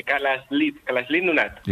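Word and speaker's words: Kalaallit Nunaat: Nada, allí cuando juntan las dos L's le ponen una S y una L. Kalalit Nunaat. – Igual que lo he dicho yo. Kalalit Nunaat.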